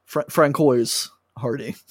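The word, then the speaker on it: Françoise Hardy: Francois Hardy. – Yeah, that's how you say it.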